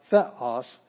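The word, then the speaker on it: Theos (θεός): Theos, not theos, it's theos. That's the one, that's the syllable you're going to hit the hardest.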